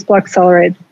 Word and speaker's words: Bloccelerate: Yep, Mitzelmaker, and it's Block Accelerate.